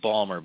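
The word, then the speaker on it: Ballmer: And by the way, gentlemen, do you know how much – is it Balmer? Is that how you pronounce his name, Balmer or Balmer? – I thought it was Balmer, but I don't know. – Yeah, it's Balmer.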